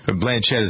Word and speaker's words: Blanchett: I guess it was Blanchett is how you pronounce it, right? – Blanchett, uh-huh.